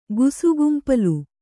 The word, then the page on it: ಗುಸುಗುಂಪಲು: gusugumpalu